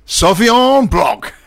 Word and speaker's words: Sauvignon Blanc: Sauvignon Blanc.